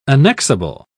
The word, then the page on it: annexable: British English